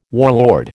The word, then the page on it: warlord: American English